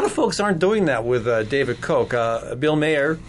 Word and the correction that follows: Bill Maher: I'm saying it wrong, says he says, "Okay, the family unfriendly parts of what Bill Maher says."